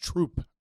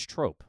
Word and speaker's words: trope: It's pronounced troop, I believe. – No, it's trope.